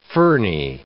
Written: ferny

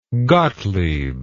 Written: Gottlieb

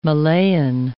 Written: Malayan

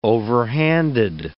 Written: overhanded

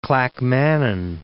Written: Clackmannan

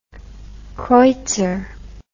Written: creutzer

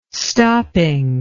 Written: stopping

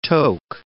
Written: toque

